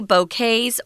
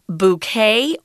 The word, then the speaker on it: bouquet: So she asks if they have any bouquets, or you can uh, pronounce this word bouquet or bouquet. It's a French word. That's why we don't say the T at the end, and it's kind of mm.